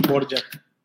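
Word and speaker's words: Dvořák: Quizás este se, se pronuncia diferente, ¿no? Pero normalmente pues, en Occidente o acá en, este lado del mundo les decimos Borjak.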